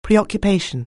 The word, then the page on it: preoccupation: anglais